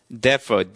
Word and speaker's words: defer: defer, D-E-F-E-R, defer라는 것은 일단 in deference to는 out of respect, 존경하여라고 써주시기 바랍니다. 존경하여. 명사할 때는 deference가 되는데 동사가 될 때는 뒤에 강세가 있습니다. D-E-F-E-R, defer라고 읽습니다.